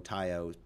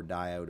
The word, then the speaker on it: Tao: It's T-A-O, Tao, pronounced Tao or Dio, depending on if it's Chinese or Korean.